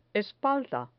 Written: espalda

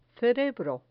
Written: cerebro

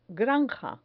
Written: granja

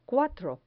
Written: cuatro

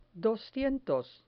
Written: doscientos

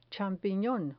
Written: champinon

